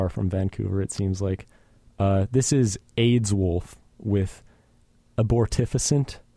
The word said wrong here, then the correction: Abortifacient: We started out with Abortifacient by AIDS Wolf. I think that's a better pronunciation.